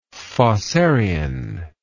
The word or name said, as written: fossarian